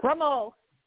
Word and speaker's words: Gremaud: Did I say it right? Gremaud? Welcome to the show, guys. – Gremaud.